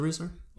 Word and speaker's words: Risner: Is it Risner? – Yeah.